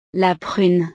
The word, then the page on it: la prune: frances